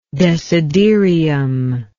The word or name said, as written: desiderium